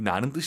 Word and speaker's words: 拿: 이 나는 뜻이 많아요. 정말. 그리고 한국어랑 같은 발음이에요.